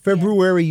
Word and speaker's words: February: February, you actually have to work at if you're going to stick that R in there.